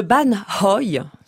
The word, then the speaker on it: ban hoi: Le ban hoi, ça, il faut prononcer le H.